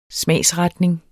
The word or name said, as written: smagsretning